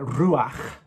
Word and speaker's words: Ruach: I think it's quite important to go back to the Old Testament and look at Ruach, R-U-A-H. There's a literal spelling of it, but some people quite commonly spell R-U-A-C-H to help us pronounce it correctly.